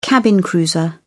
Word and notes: cabin cruiser: British English

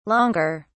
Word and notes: longer: British English

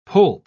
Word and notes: poulp: British English

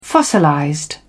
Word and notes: fossilized: British English

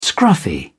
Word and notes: scruffy: British English

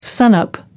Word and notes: sunup: American English

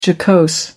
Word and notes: jocose: American English

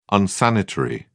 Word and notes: unsanitary: British English

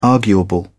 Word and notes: arguable: British English

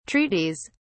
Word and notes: treaties: British English

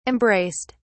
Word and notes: embraced: British English

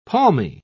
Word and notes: palmy: British English

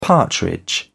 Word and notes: partridge: British English

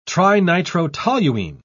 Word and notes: trinitrotoluene: British English

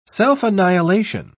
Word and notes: self-annihilation: British English

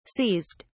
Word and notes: seized: British English

